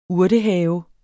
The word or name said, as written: urtehave